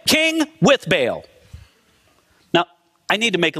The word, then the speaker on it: Baal: Friends, from my studies, I think I have learned that the correct pronunciation of the name of that pagan god is Baal. But for some reason, very early in my Christian life, it got ingrained in me that his name is Baal.